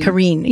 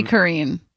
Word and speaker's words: Caryn: So it's spelled C-A-R-Y-N. Would you say Karen or Kareen? – Kareen. – Yeah, like I was looking at it both ways. – It's got to be Kareen.